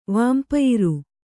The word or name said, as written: ವಾನ್ಪಯಿರು